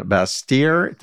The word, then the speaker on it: Bastyr: You're a member of the American and Washington Association of Naturopathic Physicians, attended the accredited university focused on natural medicine, Bastyr. Did I pronounce that correctly? – That's correct, yeah.